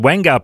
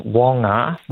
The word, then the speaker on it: Wongar: app and website called The Wangar Project. Tell us all about that. – Yeah. So look, I mean, I'll get your pronunciation. So it's Wongar. – Wongar. There you go.